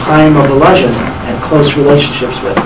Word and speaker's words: Volozhin: V-O-L-O-Z-H-I-N. – Volozhin. – That's pronounced Volozhin. – Okay. Volozhin. Thank you.